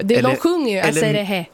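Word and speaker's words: Aserejé: Men hur skulle du uttala det här? – Asserehe? – Ja. – Asserehe? – Ja.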